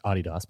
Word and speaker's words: Adidas: It's actually pronounced Adidas, but whatever.